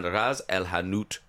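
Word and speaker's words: ras el hanout: We'll listen to some George Michael, and then we'll come back and find out exactly how you make the date and Ras Al Hanout chicken stew. Is that how you say it? – That's perfect.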